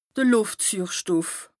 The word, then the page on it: de Lùftsüürstoff: Bas Rhin